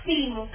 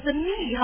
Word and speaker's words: theme: 这就是那 theme 吗？主题，这才是我要表示的精神，是不是啊？ theme 看到了哈，这几个读的时候别读成 the me 哈，人家不知道你说什么，对吧？记得哈， theme 哈，要记得。